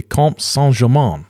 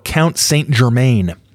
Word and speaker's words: Comte Saint-Germain: There's also a fairly lengthy article on well-known New Age and less well-known historical figure, the Comte Saint-Germain, or Count Saint-Germain, as we pronounce it here in the Midwest, and how I always pronounce it.